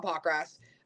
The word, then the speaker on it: Pockrass: You know, we all know who Bob is, Bob Pockrass. I'm probably saying his name wrong. I think you nailed it.